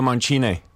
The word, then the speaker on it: Mancini: Mancini. Přesně tak. – A pozor, špatná výslovnost. Je to mančíny, takže bod pro mě.